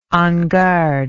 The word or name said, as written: en garde